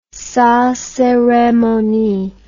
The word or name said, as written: sans ceremonie